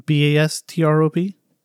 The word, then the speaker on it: Bastrop: So in the small town of Bastrop, I think I'm saying that right, Bastrop? – Yeah.